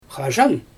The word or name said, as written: xaʒan